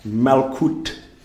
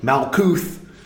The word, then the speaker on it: Malkut: Gebura, Malkut, not Malkuth.